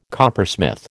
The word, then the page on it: coppersmith: American English